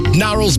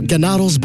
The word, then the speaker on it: Gnarls: Gnarls Barkley there, not Gnarls Barkley. How was that to know? It was a silent G.